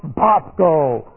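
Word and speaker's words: Bosko (βόσκω): Now we have the present active imperative command of the verb bosko. Looks like this in the Greek. Here's bosko. And that's B-O-S-K long O. Bosko.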